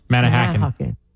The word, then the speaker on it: Manahawkin: For Manalapan, we put the emphasis on the second syllable, so it would be Manahawkin.